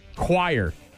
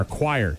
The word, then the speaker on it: choir: But yeah, well, you hear choir and you're like, oh, Q-U, right?